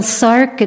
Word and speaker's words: Sark: My name is Jill Sark. It's spelled S-A-R-K.